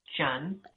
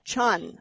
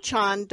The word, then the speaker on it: Tran: Most people say Tran, but actually the Vietnamese pronunciation is Chan. – Chan, okay. Yes. E.M. Tran, Daughters of the New Year is the title of her book.